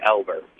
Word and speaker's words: Albert: As opposed to when it's a last name, it's pronounced with an E-L as opposed to an A-L. – Oh, okay. – So it should be Albert.